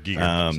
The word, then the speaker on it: Giger: Is it Giger, right. – Geiger? I never say it right. Whatever. – Giger. Sorry.